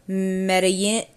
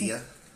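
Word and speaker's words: Medellín: So the first episode was Latin America, and he went to, okay, I'm gonna butcher the pronunciation of this, but it's Medellin, but I don't think you pronounce the L's. But it's Medea.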